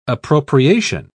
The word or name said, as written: appropriation